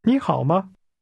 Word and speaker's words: 你好: 你好。你。你好。This word for you is pronounced with a rising tone.